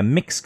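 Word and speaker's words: Mx: Like it has an I in the middle. – Yeah. – M-I-X. – Exactly. – Is that sort of how you'd pronounce it? – That's what I'm going for, yeah. And that's what I was told.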